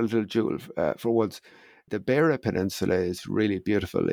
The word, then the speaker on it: Beara: Beara was B-A-R-A, Beara.